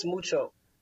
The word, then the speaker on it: Mucho: It's it the my name's spelled interestingly, but it's pronounced regardless Mucho.